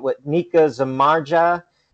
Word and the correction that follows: Zibanejad: scored five goals in one game. – Nika Zibanejad, yeah.